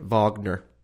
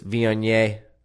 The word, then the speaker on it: Viognier: We had I kept pronouncing it Wagner. I think it's Viognier.